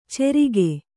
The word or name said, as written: ಚೆರಿಗೆ